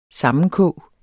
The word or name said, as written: sammenkog